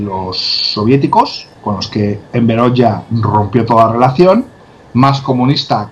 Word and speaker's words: Enver Hoxha: Escrito Oxa, pero bueno, yo siempre he dicho Enver Oxa, pero a partir ya hace unos días decidí que lo iba a pronunciar como Dios manda y es Enver Hoxha.